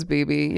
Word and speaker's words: bebe: Is it BB, Bebe? – I'm thinking it's BB.